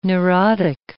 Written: neurotic

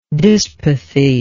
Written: dyspathetic

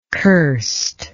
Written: curstness

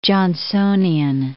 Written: Johnsonian

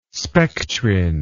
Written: spectrin

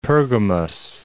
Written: Pergamos

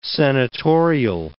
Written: senatorial

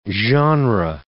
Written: genre

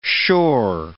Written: shore

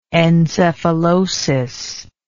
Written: encephalosis

